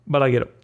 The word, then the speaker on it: Balagueró: come si pronuncia? – Balaghero, eh, che ricorderete come regista di Rec eh, e altri film horror, regista sì.